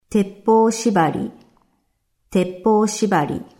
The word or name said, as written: Teppo shibari